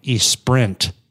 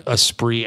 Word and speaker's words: Esprit: And that's it's spelled like Esprint. – Yeah, and I always thought that's how you said it. No, it's not. – It's Esprit.